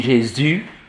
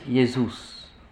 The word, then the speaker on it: Jesus: On dit Jesus. – On dit Jesus. Jesus. Mais Jesus. Jesus. On dit, nous, on dit Jésus. Et les latins disent Jesus.